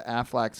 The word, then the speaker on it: Aflac: and I never put together the Aflac sounds kind of like quack. The lack part rhymes with quack.